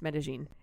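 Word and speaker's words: Medellín: But if it wasn't for that, I would never have left Medellin. – Is that how you're supposed to pronounce it? – It is. The Colombians, that double L sound is a J instead of a Y.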